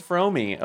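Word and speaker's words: Fromme: I had no idea it was Fromey. Okay, wow, cool. – Yeah, that's how she pronounces it.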